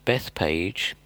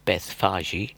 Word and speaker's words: Bethphage: Jesus, with his disciples and the pilgrims, had walked up the Jericho Road on Friday, and possibly resting at Bethpage, or as it's properly pronounced, Bethphage, which is only ever mentioned in the Bible at the recording of the triumphal entry of Jesus into Jerusalem.